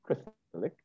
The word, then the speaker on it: Christelike: It's pronounced Christ-like. It's actually spelled like Christ-like, but there's an E between Christ and like, right?